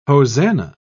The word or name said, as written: hosanna